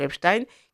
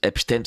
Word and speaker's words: Epstein: alors, elle prononce Epstein, parce qu'on m'envoie pas. Pourquoi parfois cette prononciation Epstein? Parce que voilà, notre amie Infocratie a l'habitude de prononcer comme les Américains le font.